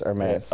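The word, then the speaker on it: Hermès: Hermes, is that how you say it? – Yeah, it's Hermes. Yeah.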